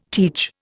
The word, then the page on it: teach: Indian